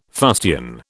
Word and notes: fustian: British English

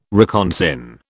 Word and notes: reconsign: British English

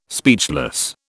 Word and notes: speechless: British English

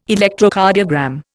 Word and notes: Electrocardiogram: Indian

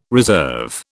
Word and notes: reserve: British English

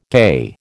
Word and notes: gay: American English

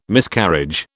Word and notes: Miscarriage: British English

